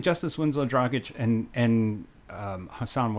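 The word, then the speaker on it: Dragić: And, like, you know, again, I think he'll work better with Jess Dragic. How do you say his name? – Dragic. – Thank you.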